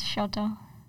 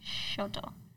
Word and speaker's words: Shoto: It's Shoto. – I know, we say Shuto half the time, though. Because but someone doesn't want to say his real name. – The Japanese pronunciation is Shoto.